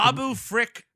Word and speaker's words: Babu Frick: And not only that, Babu Freak, or whatever his fucking Babu Frick.